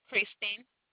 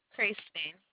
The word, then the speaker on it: Kristen: Christine. – Okay, spell it for me, please. – K-R-I-S-T-E-N. – There, I can hear you. K-R-I-S what? – T-E-N. – T-E-N, Kristen.